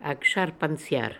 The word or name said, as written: Aksharpanziar